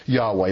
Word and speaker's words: Yahweh: For this is a Greek rendering of the Hebrew covenantal name of God, the Tetragrammaton, which is Yahweh, YHWH, pronounced Yahweh, first revealed to the Hebrew people by Moses as it was revealed to him at the burning bush.